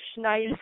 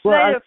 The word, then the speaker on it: Snider: It's pronounced Snyder or Schneider. – Yeah. – I say Snyder from Twisted Sister.